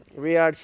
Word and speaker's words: Riyadh: Riyadh. Mm-hmm. Excuse me. To the prince, Riyadh. – Riyadh season.